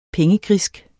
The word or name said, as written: pengegrisk